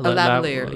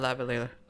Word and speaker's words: lavalier: A lavalier. Yeah, that's what I thought. A lavalier.